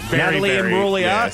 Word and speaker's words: Imbruglia: Natalie Imbruglia is how you say her name, and it is absolutely that song.